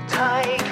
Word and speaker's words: Tige: Tige Harmon, it's spelled T-I-G-E. It's pronounced just like tiger, so take it.